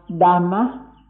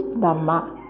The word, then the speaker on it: Dhamma: It means the word that you pronounce Dharma, D-H-A-R-M-A, Dharma or Dhamma is the same word. So we ought to understand what is Dhamma.